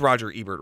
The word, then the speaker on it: Roger Ebert: It is Roger Ebert, right? That's yes. – How you say it.